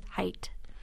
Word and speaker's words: height: But height doesn't have the TH sound at the end. It's just height.